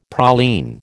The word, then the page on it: praline: American English